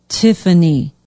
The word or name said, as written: tiffany